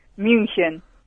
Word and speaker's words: München: Ah, ¿y en alemán? – München.